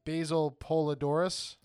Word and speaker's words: Basil Poledouris: So it was scored by Basil, I'm going to butcher this name, Basil Poledouris.